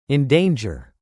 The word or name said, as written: endanger